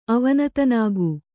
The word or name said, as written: ಅವನತನಾಗು